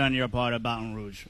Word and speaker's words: Baton Rouge: Jonathan Zaslow, ESPN Radio host, joining us live from Baton Rouge.